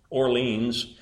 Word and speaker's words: Orléans: He moved to another college as a philosophy student, but a short time later, his father transferred him from there to the University of Orléans, which we would pronounce Orleans,